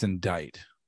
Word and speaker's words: indict: I think it's indict. – Now there's a C right there. – Don't think it's pronounced, but it can indict you. That's fine. – Well, it's it's called a hard C.